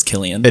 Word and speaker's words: Cillian: It is Cillian.